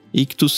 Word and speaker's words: Ichthus: Ictus se escreve I C H T H U S Obviamente o link vai estar tá aí na descrição do programa.